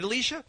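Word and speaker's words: Allichia: That's A-L-I-C-I-A. That's A-L-I-C-I-A, right, Alicia? – Yeah, well, it's Alicia, but it's pronounced Alicia. – Alicia. – And how do you spell it? – It's A-L-L-I-C-H-I-A.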